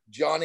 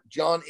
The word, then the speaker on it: Jon: But I want people go to my Instagram account, which is the John Andersen, at the John Andersen.